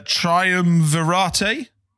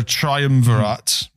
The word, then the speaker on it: triumvirate: Is it triumvirate? – Uh-huh, yes, the triumvirate. – Triumvirate. Oh, God, that's how you say it. I had no idea. – It is triumvirate in Latin, I think, but triumvirate is the English word, yeah.